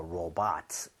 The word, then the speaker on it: robot: Into a robot. 정답? Got it, right? R-O-B-O-T. Robot. Robot. B-O-T를 bot에 가깝게 발음하셔야 돼요.